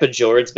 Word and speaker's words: fjords: Is it Fjords or Fjords? Because I know you guys. – I like Fjords better, but yeah, Fjords. – Okay. – You don't pronounce the J.